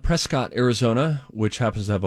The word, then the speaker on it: Prescott: It is Prescott. – Oh, Prescott. – Pronounce it Prescott.